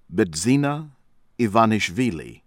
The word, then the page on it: Bidzina Ivanishvili: American English